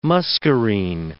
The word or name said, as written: muscarine